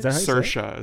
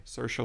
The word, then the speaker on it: Saoirse: Saoirse is how you say it. – Oh, really? – Saoirse like inertia.